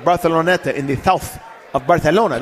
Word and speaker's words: Barcelona: Say it correctly. – That is how you say it. That's how Barcelona people say it in Barcelona.